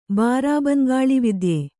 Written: ಬಾರಾಬಂಗಾಳಿವಿದ್ಯೆ

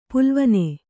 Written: ಪಾಲ್ವಣ

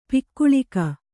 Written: ಪೊಗಳಿಕೆ

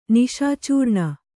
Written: ನಿಶಾಚೂರ್ಣ

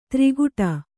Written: ತ್ರಿಗುಟ

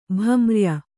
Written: ಭಮ್ರ್ಯ

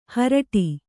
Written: ಹರಟಿ

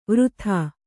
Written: ವೃಥಾ

